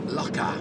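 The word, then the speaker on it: Lackagh: But anyway, Laka, AGH at the end of a word is pronounced ah. Not ach or ag or anything like that, just ah.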